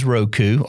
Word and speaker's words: Roku: It's probably the most mispronounced box in the industry, but it is Roku, R-O-K-U, and they're boxes, or they even have a stick.